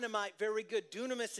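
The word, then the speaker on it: dunamis (δύναμις): Dunamis. It looks like dynamis on the screen, but it's actually dunamis in the Greek is how you say it.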